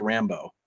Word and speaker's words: Rimbaud: Arthur Rimbaud, it's actually pronounced Rambo. – Okay. – So Arthur Rambo.